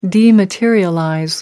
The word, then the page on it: dematerialize: American English